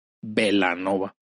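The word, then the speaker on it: Belanova: Y el problema aquí, que cuando tocaban en estos bares de Guadalajara, donde normalmente se presentaban, la gente lo pronunciaba con la doble L, o uh-huh, sea, Bellanova, ¿no? – Okay. – Entonces decidieron, pues, qué importa cómo se escriba, para que se pronuncie como el origen italiano, y decidieron quitar una L y quedar simplemente con el nombre actual, que es Bellanova.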